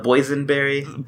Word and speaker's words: boysenberry: Is that the poison, the boysenberry? – Poison. – Well, excuse me. It rhymes with poison.